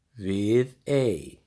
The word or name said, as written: Viðey